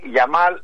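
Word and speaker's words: Yamal: Ο Κομπαρσί, ο Πέδρη και ο Λαμέγια. Συμφωνείς? – Ο Γιαμάλ λες.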